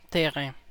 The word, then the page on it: terrain: CA; IPA: [te.ʁẽ]; French